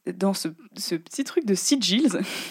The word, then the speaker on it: sigils: C'est sigils. un sigil Non, qu'est-ce que c'est Ça s'écrit S-I-G-I-L.